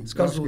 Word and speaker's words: Escazú: E pegando esse gancho da América do Sul, eu queria perguntar sobre o acordo de Escazú, essa é pronúncia correta? – Sim. Escazu, que...